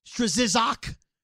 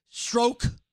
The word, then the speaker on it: Strzok: So then people are calling him Strzok. Strzizok, right? Stroke. His name is Strzok. Stop looking at how things are spelled and pronounce them the way their names are pronounced.